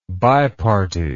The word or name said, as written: biparty